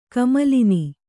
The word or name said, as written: ಕಮಲಿನಿ